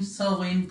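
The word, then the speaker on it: Samhain: que es Shamain, Sowin, como lo, pronuncian en, la serie, y tiene que ver con la festividad del primero de mayo, que es el solsticio de invierno.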